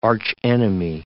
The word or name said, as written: archenemy